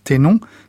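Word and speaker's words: ténô: Alors, nous avons entendu dans votre bouche, Alban Bensa, le mot « ténon » qui s'écrit en français, c'est transcrit T-E accent aigu, N-O accent circonflexe peut-être, oui, c'est ça. – Qui se prononce donc Ténon,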